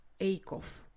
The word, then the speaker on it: Acuff: Но мне очень понравился их последний а, выпуск про книжку «Финиш» а, автор Джон Эйков, по-моему. Я посмотрела, что он вроде так произносится, потому что по-английски он пишется A C U F F и вообще непонятно, как это должно читаться. Честно говоря, они там в выпуске три разных варианта произнесения его фамилии э, при, приводят.